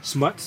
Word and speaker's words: Smotz: Smuts? – Smuts. – How do you spell it? – S-M-O-T-Z. It's like snot. – S-M-O-T-Z.